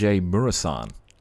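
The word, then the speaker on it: Mureșan: It is the tallest player to ever play in the NBA, and I'm going to butcher this name here, George Muresan. – That's his name. You, you nailed it.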